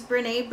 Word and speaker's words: Brené: There's a woman, you may know her already, her name's Brené Brown, and that's spelled B-R-E-N-E, Brené Brown, and she has a short, like, 20-minute video on YouTube about vulnerability, and it's really awesome.